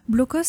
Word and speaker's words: Blokos: Blokos, ça se prononce Blokos, comme Cassos.